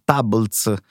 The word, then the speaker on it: Tablz: Tables, scritto T-A-B-L-Z, giusto perché la pronuncia, diciamo, sul naming forse potevano fare di meglio.